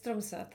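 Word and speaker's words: Tromsø: Mówiłaś, ja pewnie źle to wymawiam, ale Tromso, tak? – Tromso, tak.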